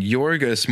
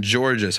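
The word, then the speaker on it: Georgios: I feel pretty good about the last one, but the first one, Yorgos, more than Georges, I think is the way they pronounce it.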